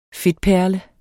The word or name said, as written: fedtperle